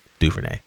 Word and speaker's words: Duvernay: And every year, man, Devin Duvernay, that's how you say it, Duvernay, came in and senior year, man, had a crazy year, man, crazy year.